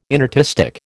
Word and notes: inartistic: American English

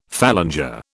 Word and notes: phalanger: British English

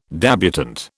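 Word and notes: débutante: British English

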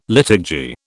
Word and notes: liturgy: British English

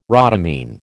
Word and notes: rhodamine: American English

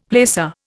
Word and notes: placer: Indian

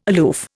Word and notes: aloof: Indian

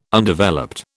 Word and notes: Undeveloped: British English

